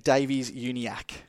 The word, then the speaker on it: Davies-Uniacke: How do you pronounce it? – Luke Davies-Uniacke. – Uniacke.